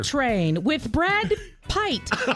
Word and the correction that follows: Pitt: stays at number one. – That's Pitt.